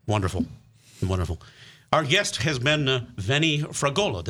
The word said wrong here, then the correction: Fragosa: Did I pronounce that correctly? – Fragosa. – Fragosa. Thank you.